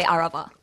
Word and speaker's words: Arava: Except I would say Arava. – Okay. – It's kind of like the emphasis is different, but very similar. – Arava.